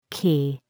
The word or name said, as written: key